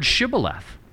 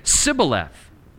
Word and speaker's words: Shibboleth: And they would say, "All right, say the word Shibboleth." And he would say, "Sibboleth," because they couldn't pronounce it.